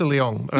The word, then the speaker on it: Leong: I would say Melissa Leong.